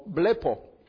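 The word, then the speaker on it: blepo (βλέπω): Y aquí la palabra ver, en griego, es una palabra que se pronuncia más o menos como blepo.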